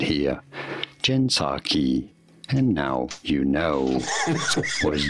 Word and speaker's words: Jen Psaki: Anyway, Jen Psaki. The P is silent.